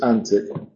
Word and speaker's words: antic: En inglés moderno es antique, sobre todo es un sustantivo en inglés moderno. Aquí es un adjetivo y es antic, se pronuncia.